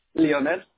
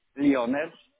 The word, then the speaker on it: Leonel: Leonel. – One more time? – Leonel. – Oh, will you spell that for me, please? – L-E-O-N-E-L.